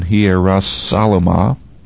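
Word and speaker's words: Hierosalem: Hierasalama, the I being pronounced as a J sound in English.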